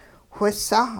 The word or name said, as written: whisah